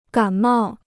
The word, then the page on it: 感冒: gǎn mào